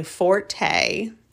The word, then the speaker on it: forte: You know by now, technology is not my forte, or fort as it is actually pronounced, I learned a couple years ago.